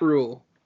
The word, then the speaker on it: Rhule: I think you just like the silent H in his name because it makes you feel cultured. But anyways. – Man, Matt Rhule has been incredible at Baylor.